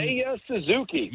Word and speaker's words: Seiya Suzuki: I mean, Seiya Suzuki.